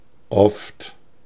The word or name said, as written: often